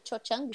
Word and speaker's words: Cho Chang: Eu chamo de Xoxeng, se você quiser corrigir minha pronúncia. – É, o Cho, Cho, né? Cho Chang.